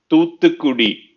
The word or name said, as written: Thoothukudi